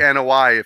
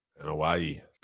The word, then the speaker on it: Anoa'i: Or the Anoa'i, if you want to say it correctly. – Anoa'i.